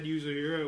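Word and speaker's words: Yuzuriha: I'm particularly proud of Yuzuhira because it's Yuzuhira. Because I couldn't get that right until today. Took me ages. I watched the sub and every time they said Yuzuhira, it was just buried in Japanese, so I couldn't get it.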